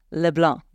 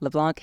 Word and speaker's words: LeBlanc: It is Dylan LeBlanc, not LeBlanc.